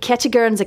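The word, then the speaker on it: Kentigern's: So he has been, he went back to his St. Now you can maybe correct my pronunciation. St. Ketchigern's Academy in Blackburn, West Lothian. – Sounds flawless to me.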